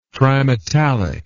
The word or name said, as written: trimetallic